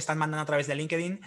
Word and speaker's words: LinkedIn: Eh, es que aquí en España decimos LinkedIn. Sé, que en inglés se dice LinkedIn, ¿vale?